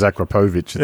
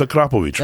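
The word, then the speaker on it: Akrapovič: That's how it's pronounced. It Akrapovic, right? – Everyone says Akrapovic. – It's not. It's Akrapovic, right? – Akrapovic.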